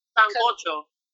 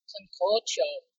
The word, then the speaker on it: Sancocho: Sancocho. – Sancocho.